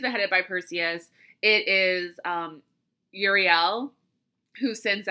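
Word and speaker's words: Euryale: And then we have Uriel. I always say her name wrong. I mean, I always say her name wrong since I started doing the research for this, but it is E... U-R-Y-A-L, Uriel, and her name means far roaming, and she is known for her bellowing and fearsome cries.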